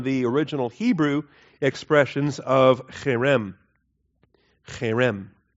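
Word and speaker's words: cherem (חרם): And allergy season is great for pronouncing cherem, because you've got to get that in the back of your throat, okay? Or any German speakers that are accustomed to the guttural stuff back there, all right?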